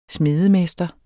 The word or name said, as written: smedemester